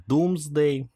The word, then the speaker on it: Domesday: который назывался Domesday, но, естественно, все участники произносили его как Doomsday.